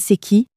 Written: c'est qui

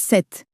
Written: sept

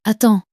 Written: attends

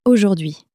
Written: aujourd'hui

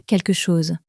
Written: quelque chose

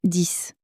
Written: dix

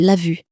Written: vue

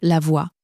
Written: voie

